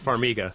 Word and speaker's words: Farmiga: Farmiga? Am I pronouncing that right? – I think it's Farmiga.